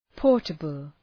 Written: portable

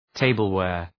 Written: tableware

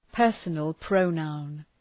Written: personal pronoun

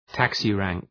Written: taxi rank

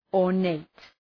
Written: ornate

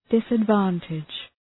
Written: disadvantage

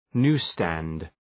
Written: newsstand

